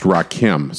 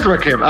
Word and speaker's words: Rakim: Which and tells you last week's episode where we talked about the upcoming record, I did not realize his name was pronounced Rakim. So I said Rakim, and then almost immediately thereafter, I started looking and was like, oh, I said it wrong. So, it is Rakim. All right. Until now, I thought it was Rakim.